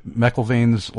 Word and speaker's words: McIlvaine: And did I pronounce Bishop McIlvaine's last name correctly? – That is how I pronounce it, yes.